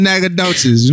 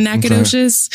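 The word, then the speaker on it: Nacogdoches: You just ignore the G. That's it. Just take out the G that's in Nagadoches, okay.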